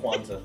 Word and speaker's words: Rayquaza: It's Rayquaza. Am I saying that right? – Rayquaza. – Rayquaza. There's no N in here.